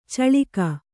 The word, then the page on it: ಚಳಿಕೆ: kannada